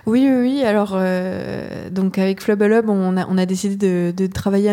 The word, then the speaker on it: FLBLB: Je ne sais jamais s'il faut dire FLBLB ou justement Flubble. – Comme on est en direct et qu'ils nous regardent. – Tu fais très bien, Flubble.